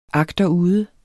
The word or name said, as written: agterude